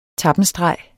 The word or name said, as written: TAPPENSTREGEN